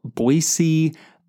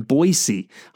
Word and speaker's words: Boise: And just a friendly reminder to those of you listening to this podcast from outside the Treasure Valley, there is no Z in Boise. So just remember as you pronounce that.